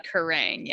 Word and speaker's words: Kerrang: I say Kerrang, yeah.